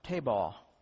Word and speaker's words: tebah: And the Hebrew word for ark is pronounced tebah, meaning a box.